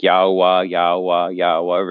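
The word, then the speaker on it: Yahweh: Yeah, so My understanding of Yod Heh Vav Heh, which the most correct pronunciation that I've found is Yao Wah. It's all vowels or vowel sounds or whatnot.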